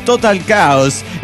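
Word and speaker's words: Total Chaos: En realidad es Total Caos, pronúnciese así.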